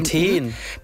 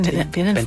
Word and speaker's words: Benten: Ich als Einheimische aus MV frage mich immer, wie Benten bei Lübs ausgesprochen wird, also mit langen oder mit kurzem E hinten. Benten. – Benten. Benten. Nee, nicht Benten. Hinten. Nee. Wenn dann, Benten.